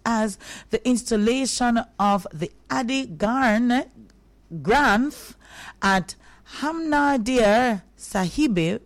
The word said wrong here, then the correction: Harmandir Sahib: let's get that right, Harmandir Sahib, the holy scripture of sixth.